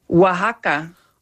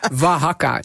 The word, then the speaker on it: Oaxaca: Hoe moet je nou uitspreken? – Oaxaca. – Oh, dat is heel wat anders. Oaxaca.